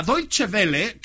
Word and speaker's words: Deutsche Welle: La Deutsche Welle, que es un canal que mañana la presidenta...